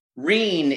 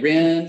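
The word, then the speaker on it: Rihn: If it was to be spelled like it was German, and I will say the pronunciation varies on the show, even. – Okay. They'll say Reen, they'll say Ren.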